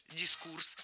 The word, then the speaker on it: дискурс: А запомнить ударение в этом слове легко с помощью другого простого слова «курс». Курс – дискурс.